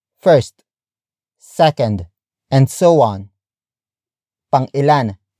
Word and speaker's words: Pang-ilan: Pang-ilan. Pang-ilan.